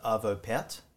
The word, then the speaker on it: Arvo Pärt: Estonian composer Arvo Pärt, is how you pronounce his name, I'm led to believe. – Arvo Pärt, which is kind of the way you read it off the page, yeah.